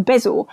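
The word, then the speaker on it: bezoar: I think it's pronounced bezor, which is from an Arabic word meaning antidote.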